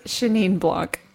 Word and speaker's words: Chenin Blanc: And I'm going to say Llano Estacado, and I think it's a Chenin Blanc or something like that. How do you pronounce it? – Chenin Blanc. Yes. – Is that right? – That's correct.